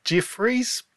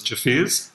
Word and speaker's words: Jeffers: I was probably going to go with something like Jeffries, but that doesn't seem quite right. – Yeah, so that's pretty close, and we do get a whole lot of different ways of people pronouncing it, but the correct pronunciation is Jeffers.